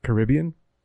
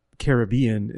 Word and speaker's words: Caribbean: Caribbean ends up being the proper, uh, I guess what they call the most respectful pronunciation to the island natives.